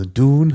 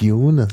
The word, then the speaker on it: Dune: Also Dune heißt es und nicht Dune, das ist so eine bisschen britische Aussprache.